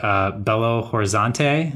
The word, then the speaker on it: Belo Horizonte: And so you grew up in a city called, I'm going to totally butcher this, but Belo Horizonte. Is that really good? – Is that close? – That's close. That is close.